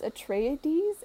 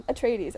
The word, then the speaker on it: Atreides: This week's comic review is of Dune House Atreides. Atreides, I said it right the first time, by Brian Herbert, Kevin J.